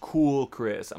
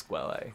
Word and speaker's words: Quelle: Who one of our listeners was like, no, it's pronounced cool, Chris. I'm like, no, it's Quelle.